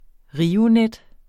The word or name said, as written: Rionet